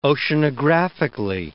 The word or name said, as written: oceanographically